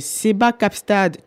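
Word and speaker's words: Seba Kaapstad: Euh, avant d'enchaîner avec, euh, avec les, les crushs musicaux, on va prendre une petite pause pour s'écouter euh, le titre de Seba Capstad. Tu me, tu me dis si je prononce mal. Non, ah, c'est bon.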